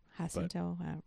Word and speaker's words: Jacinto: With art by Kim Jacinto. I guarantee I pronounced that wrong. – Jacinto. This was the long-awaited resolution of the Punisher versus Thunderbolts storyline.